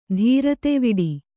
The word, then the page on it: ಧೀರತೆವಿಡಿ: Kannada; dhīrateviḍi